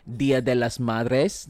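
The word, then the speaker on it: Día de las Madres: Of course, tomorrow is Mother's Day and also Dia de las Madres. Did I say it right, babe? – Yes, you did.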